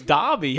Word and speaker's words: Dobby: Like Adobe? – No, it's Dobby.